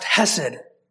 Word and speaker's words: hesed: It's pronounced hesed.